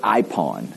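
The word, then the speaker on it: ipon (εἶπον): It's a Greek word, ipon, and I just spell it EYE-PAHN. I don't even write the words out anymore. I come up with my own way of saying it correctly.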